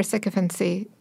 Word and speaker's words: sycophancy: He has set new standards for sycophancy, you know, a word that I don't know how to pronounce. – Sounds right. – You nailed it.